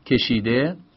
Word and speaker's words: کشیده: یعنی مثلا شما میگید که کشیده، کشید بن ماضیه، یه های غیر ملفوظ هم بهش اضافه میکنیم. چرا بهش میگیم غیر ملفوظ؟ چون ه ه تلفظ نمیشه، میگیم کشیده، کشیده نمیگیم.